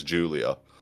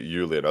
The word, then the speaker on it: Yulia: I was convinced that it was Julia until her dad turned up with the thick Russian accent and was pronouncing it Yulia. And I was like, well, obviously he's going to pronounce it Yulia. That's his accent coming through. And then after that, other characters seemed to be calling her Yulia. And I was like, oh, maybe it's been Yulia all along then, and I've just not been listening to it.